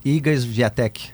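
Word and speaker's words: Iga Świątek: Iga Swiatek, é a polonesa, número um do mundo.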